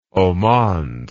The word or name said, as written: haut monde